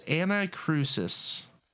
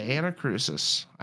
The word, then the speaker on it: Anacrusis: No, it's Anacrusis. The Anacrusis. I know, I'm pretty sure that's how you pronounce that.